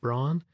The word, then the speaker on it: Braun: Most Canadians or Americans would pronounce that Braun, and you're probably familiar with their radios and their shavers and kitchen appliances.